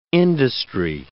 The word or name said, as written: industry